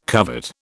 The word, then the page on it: covet: British English